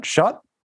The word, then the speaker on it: Shutt: And Kyle Shutt. How do we pronounce that? Shutt? – Yep, Kyle Shutt.